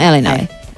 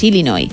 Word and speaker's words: Illinois: Pero es Illinois, o sea, si tú lo pronuncias bien y tienes buena dicción, es Illinois. – Illinois. – Luego, si lo pronuncias rápido, según el acento, como decía Beto también, pues te sale una medio E, pero nunca un Illinois.